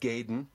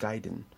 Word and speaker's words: Gaiden: so gerne ausgesprochen wird, Gaiden. Für die, äh, die Unterricht nehmen oder nehmen wollen, es ist Gaiden.